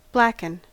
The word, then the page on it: blacken: American English